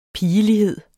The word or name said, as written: pigelighed